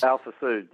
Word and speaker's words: Alfa Sud: Alfa Sud, yeah.